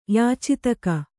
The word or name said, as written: ಯಾಚಿತಕ